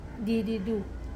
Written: adidu

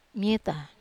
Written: meta

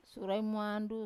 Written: sura e muandu

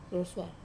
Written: rosua

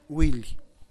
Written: gwilly

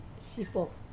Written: sapop

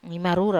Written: marura

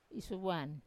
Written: isuvuan

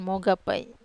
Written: moga

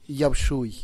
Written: yagbtxui

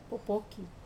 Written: popoki

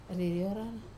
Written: rariorar

